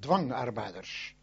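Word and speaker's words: dwangarbeiders: In Holland, they call it dwangarbeiters. In German is zwang. We pronounce it with a D and they with a Z, zwang. But we say dwangarbeiters and say zwangarbeiters. You see, that's the difference.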